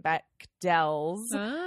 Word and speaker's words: Bechdel: after seeing that, I now know that we were just horribly mispronouncing her name through that entire episode. – Oh, it's Bechdel. – Okay. – Not Bechdel. Take note.